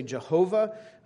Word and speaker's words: Yahweh: We said that transliterated, it might be pronounced Yahweh, or some people still say Jehovah, which is a combination of those consonants for Yahweh and the vowels for Adonai, and kind of come together.